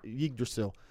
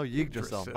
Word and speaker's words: Yggdrasil: But what I like about paganism is that it's how do you pronounce it? – Yggdrasil. – Oh, Yggdrasil. My bad.